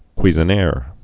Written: Cuisenaire

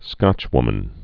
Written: Scotchwoman

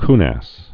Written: coonass

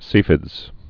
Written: CFIDS

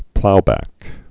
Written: plow back

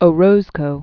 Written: Orozco

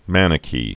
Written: Manichee

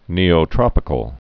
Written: Neotropical

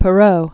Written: Charles Perrault

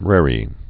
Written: raree show

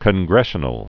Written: congressional

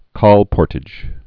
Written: colportages

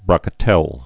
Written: brocatelle

